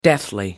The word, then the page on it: deathly: American English